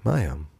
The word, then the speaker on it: Maye: M-A-E oder wie schreibt man May? – M-A-Y-E. – M-A-Y-E. Maya. So wie Mayo, nur mit E hinten.